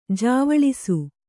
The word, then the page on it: ಜ್ವಲಿಸು: jvalisu